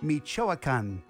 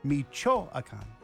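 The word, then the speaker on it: Michoacán: Right now, I want to talk about, let's see if I can pronounce this correctly, Michoacan, Mexico. All right. Is that how you say it? I did it phonetically. Michoacan.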